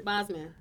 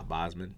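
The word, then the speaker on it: Boseman: Boswick, right? Bosman. – Bosman. Yeah, I think that's how you pronounce the last name.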